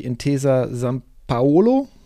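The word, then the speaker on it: Intesa Sanpaolo: Und an dritter Stelle die Intesa San Paolo. Korrigiere mich, wenn ich sie nicht richtig ausspreche. – Du hast es richtig ausgesprochen.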